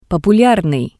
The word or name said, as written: populyarnii